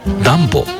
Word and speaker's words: DUMBO: ニューヨークを知っている方でもダンボという名前を聞いたとき、少し首をかしげることがあります。 DUMBO と書いてダンボと読みます。